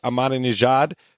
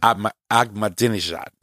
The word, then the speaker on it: Ahmadinejad: So back in September 14th of 2007 in New York, Iranian President Mahmoud Ahmadinejad claimed. – You said it wrong. – I'm not good at saying his name. – Ahmadinejad. – Ahmadinejad. I was close.